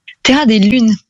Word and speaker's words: Terra des Lunes: Je l'ai bien prononcé, Terra des Lounes. – Terra des Lunes. – Des Lunes, oui, voilà, oui, oui. – C'est un occitan.